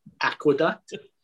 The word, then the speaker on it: Aqueduct: I went to, I'm going to try and say it correctly the first time, Aqueduct. Did I say it that time? That I was right? – That's correct, as long as you stopped and got a gyro on the way home.